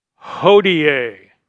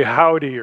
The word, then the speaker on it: hodie: Christus natus hodie. Now that doesn't look like you would say, it looks like you'd say howdy or something, but it's actually pronounced hodie.